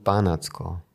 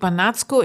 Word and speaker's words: Banatsko: Ja, ich muss es auf der ersten Silbe betonen, Barnardsko. – Äh, ja, es kommt, ist unterschiedlich. Es gibt Banatsko in manchen Sprachen, wo es auf der zweiten Silbe, aber im Ungarischen betont man immer auf der ersten.